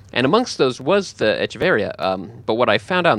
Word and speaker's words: Echeverría: He's Antanasio Echeveria. The plant is Echeveria, which only has one R, but he is Echeveria with the two R's in the accent on the I.